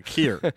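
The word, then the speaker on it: Kier: I'm glad you told me how to pronounce her name, because I was scared to... Keir, it's Keir.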